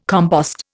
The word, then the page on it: compost: Indian